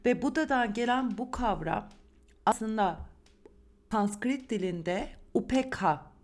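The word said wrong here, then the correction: upekṣā: deniyor. Ya, yani Upeksha diye okunuyor.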